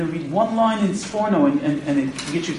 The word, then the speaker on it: Sforno: The correct pronunciation is Sforno. Sforno should be Sforno. Okay, it's the name of a city in Italy.